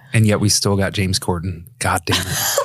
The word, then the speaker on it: James Corden: Am I saying his name right? – James Corden. – James Corden's, she's like the other guest that's on that episode.